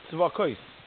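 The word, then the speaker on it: Tzevakos (צבאות): One of the names of Hashem, you saw I pronounced it Tzevakos. I didn't pronounce it the way it says it. Aleph of Sav should be Ois.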